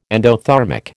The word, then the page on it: endothermic: American English